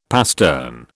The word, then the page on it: pastern: British English